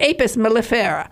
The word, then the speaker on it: Apis mellifera: The Busy Life of the Apis mellifera.